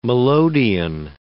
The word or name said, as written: melodeon